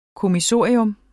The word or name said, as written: kommissorium